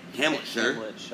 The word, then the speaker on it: Hamletshire: Kellen plays a deputy who's been assigned to the village of Shieldmore, or of Hamletshire. Spelt Shire, but as I've learned from watching Top Gear, anytime that's spelled that way, it's Shire.